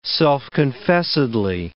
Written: self-confessedly

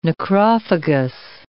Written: necrophagous

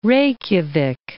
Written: Reykjavik

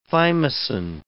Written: thymosin

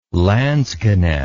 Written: lansquenet

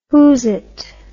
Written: whoozit

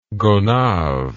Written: gonave

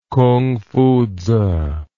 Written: kungfutzu

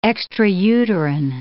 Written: extrauterine

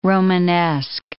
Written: Romanesque

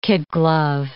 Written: kid-glove

